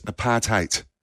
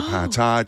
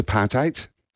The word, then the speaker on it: apartheid: Earl Reeve also taught us apartheid is the right pronunciation. People say apartheid, apartheid. – Yep. – We were taught it's apartheid. And that's the only way to say it correctly.